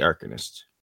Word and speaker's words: Arcanist: Yeah, Arcanist or Arcanist, however you want to pronounce that. I say Arcanist. I think everybody else was saying Arcanist. – I'm pretty sure Arcanist is the correct pronunciation of that word.